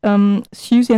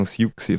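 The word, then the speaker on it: Siouxsie: Ausgesprochen Siuxi, falsch ausgesprochen Siuxi. – Siuxi, ja, es wird also buchstabengetreu ausgesprochen. Also es ist eine Anspielung natürlich an den Indianerstamm. Und wird ausgesprochen richtig Suzy, oder ne, Suzy nicht ganz so, aber wie, ja, ungefähr wie Suzy.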